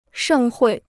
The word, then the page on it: 盛会: shèng huì